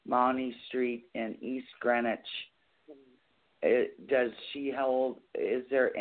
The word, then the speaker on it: East Greenwich: East or Eve's? – East Greenwich. That's how it's pronounced.